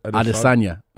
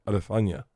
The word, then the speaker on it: Adesanya: How do you say it? Israel Adesanya. – Adesanya. Mm-hmm.